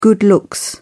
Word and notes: good looks: British English

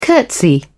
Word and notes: curtsy: British English

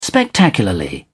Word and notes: spectacularly: British English

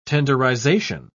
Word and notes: tenderization: British English

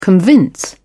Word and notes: convince: British English

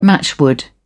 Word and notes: matchwood: British English

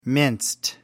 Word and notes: minced: American English

